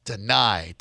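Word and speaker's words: Danai: She is played by Danae Gurira, and I hope I pronounced that right. Did I get that right, Danae? – Deny. – Of course, deny.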